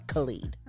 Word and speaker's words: Khalid: They're going to have people uh, like Chaka Khan, Khaled, not your Khaled Milan, the singer. Oh, I'm sorry, Khalid.